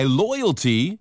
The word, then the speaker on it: loyalty: A-B-I-L-I-T-Y, ability고요. 하나 주의하세요. loyalty 할 때 발음이 L-O-Y-A-L-T-Y, 러러러. – Loyalty. – 예, 이거는 충성심이고요. R로 시작하면 Royalty.